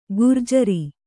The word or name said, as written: ಘೂರ್ಜರಿ